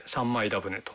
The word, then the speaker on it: 三枚田舟: でそこを降りたところすぐのところに木製の平底舟が置かれていまして、これは三枚田舟三枚の板の船と書いて三枚田舟と読むんですが、